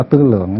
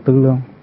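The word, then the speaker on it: tư lượng (思量): Chữ tư lương này á, nó là vốn là tư lượng, nhưng mà mình thường đọc là tư lương, tư lượng.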